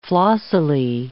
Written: flossily

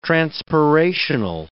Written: transpirational

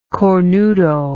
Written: cornuto

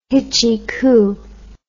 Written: hitchykoo

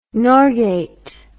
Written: Norgate

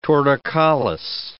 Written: torticollis

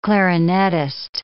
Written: clarinettist

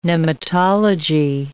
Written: nematology

